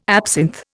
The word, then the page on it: absinth: Indian